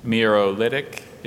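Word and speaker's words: miarolitic: Mirolytic is the only pronunciation.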